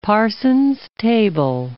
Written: Parsons table

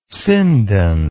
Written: sindon

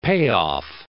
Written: payoff

